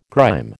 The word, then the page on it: grime: American English